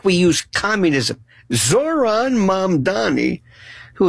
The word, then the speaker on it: Mamdani: his name, mom and dad, mom, Donnie. My mom, Donnie, you know, my mom's name is Donnie. It's so simple.